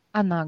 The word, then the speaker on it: on: Для любителей разговорной речи повторяю, что местоимение «ну» вы всегда можете заменить частицей «он», которая пишется как «он», произносим в нос. «Он».